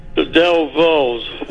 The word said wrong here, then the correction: Dovells: I'm not saying it right. – That's all right. You know it's the Dovells, and I'll give it to you for that.